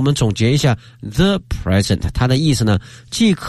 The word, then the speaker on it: present: 所以大家看到 the present 它常用的意思呢有三类啊。接下来看看这个单词，它作为动词的发音是 present. – 哎，这个重音放到第二个音节了，大家要特别的注意啊，present。 – 哎，present 这个词哈，那么这个词呢，如果大家小心留意的话呢，会经常听见。